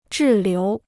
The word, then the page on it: 滞留: zhì liú